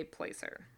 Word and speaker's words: placer: Yeah, and so I've heard placer and placer. How have you heard it? – I was waiting for you to pronounce it. I have also heard both, but I say placer. – Okay. Yeah, so I guess, you know, being south, southern U.S., we would go for the long A sound.